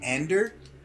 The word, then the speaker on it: Andor: If you're on a mission with Antor, and you're going to have someone cover for you, it's not going to work. It happened on Scarif. It did not work. – It's pronounced Ander.